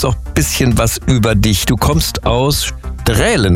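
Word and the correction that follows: Straelen: ne? – Strahlen wird das ausgesprochen. – Oh mein Gott, jetzt habe ich schon wieder einen Fehler gemacht.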